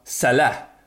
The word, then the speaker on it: Salah: Ska det sägas Bruno Fernandes och Timo Werner, då ska det ju också på bästa, alltså Sveriges radiokorrespondent i Mellanöstern, Salah självklart uttalas med de arabiska A-na. Alltså, du uttalar Salah som Hezbollah, Hamas, Abu Bakr al-Baghdadi.